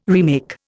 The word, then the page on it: remake: Indian